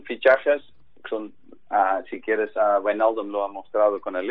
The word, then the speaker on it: Wijnaldum: Pronuncia otra vez Wijnaldum. – Wijnaldum. Wijnaldum. – Perfecto, pues ya sabes cómo es.